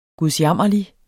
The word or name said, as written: gudsjammerlig